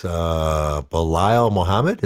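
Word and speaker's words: Belal Muhammad: Next up, Lyman Good versus Belial Muhammad. Is that how you say it? – It's actually pretty goddamn close. Yeah. Good job.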